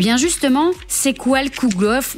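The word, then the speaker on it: Kugelhopf: Comment prononce-t-on ça encore, dites-moi? – Alors, c'est le Kugelhof. – Kugelhof, voilà, ce sera plus facile.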